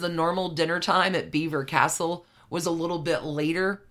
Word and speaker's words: Belvoir: If you are reading the word, you're never going to know that it's pronounced Beaver Castle. And the spelling of this castle is B-E-L-V-O-I-R.